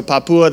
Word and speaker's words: Papua: So Papua, that's how they say it, apparently.